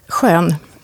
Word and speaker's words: Skön: Idag ska vi prata med Ylva, hur uttalar man ditt efternamn? – Skön.